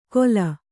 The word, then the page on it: ಕೊಲ: kola